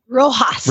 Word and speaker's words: Rojas: Well, there's this trainer named Murray Rojas, R-O-J-A-S. How do you say that? – Rojas. – Rojas, thank you.